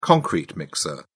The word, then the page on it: concrete mixer: British English